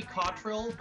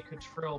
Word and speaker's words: Cottrell: It's supposed to be Cottrell, but I don't correct people when they say Cottrell because it sounds classier.